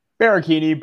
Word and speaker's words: Baracchini: Baracchini, but I've Baracchini, heard it all, okay.